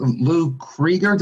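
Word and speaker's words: Criger: Could you talk about his relationship with Lou Krieger? Did I pronounce that right? – Yeah, one of his catchers, right.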